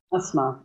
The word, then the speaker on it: Asma: And his wife, how does she say her name? Asma? – Asma, yes.